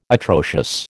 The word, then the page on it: atrocious: American English